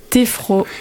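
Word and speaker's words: téphro: Avec téfro, téfro. – Téfro, ça s'écrit T-E-P-H-R-O.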